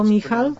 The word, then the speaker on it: Michal: Wyszukałem to słowo Michal. Podobnie się wymawia jak anioł Michał.